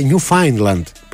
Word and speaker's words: Newfoundland: Για το φίλο που λέει Ακτύπη, επειδή έχεις πάει πολύ πέρα από το proficiency και σε αρέσουν αυτά, το Newfoundland προφέρεται Newfoundland πληροφοριακά.